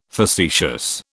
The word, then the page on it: facetious: British English